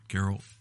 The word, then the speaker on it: Geralt: Geralt, or how do you say his name? – Geralt.